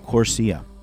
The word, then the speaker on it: CORSIA: Airlines will be able to offset emissions through something called the Carbon Offsetting and Reduction Scheme for International Aviation, or CORSIA, pronounced CORSIA, which could end up putting a lot of money into environmental programs.